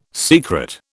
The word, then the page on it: secret: British English